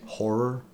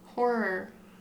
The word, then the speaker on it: horror: Horror. – Horror. Horror. – There you go. – I'm not. – Horror. There's an R. There's an O-R situation, not an E-R situation. Horror.